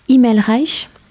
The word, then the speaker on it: Himmelreich: qui s'appelle Laura Himmelreich ». C'est comme ça qu'on prononce.